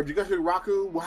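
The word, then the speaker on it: Jigokuraku: Cause so far we're watching Knuckle Blaster and Jigoku Raku, Jigoku Rockin. Or Jigoku Raku, how it's pronounced, the Hell's Paradise joint.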